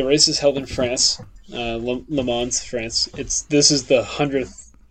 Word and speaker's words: Le Mans: but it's the 24-hour Le Mans. – Yep, 24 hours tournament. Le Mans.